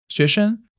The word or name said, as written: 学生